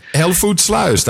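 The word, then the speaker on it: Hellevoetsluis: It well, says Hellevoet Slouse. That's how you pronounce it.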